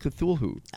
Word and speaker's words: Cthulhu: Cthulhu, and this says, may I please buy a vowel, Pat, because this is spelled C-T-H. – Yeah, C-T-H. – U-L-H-U.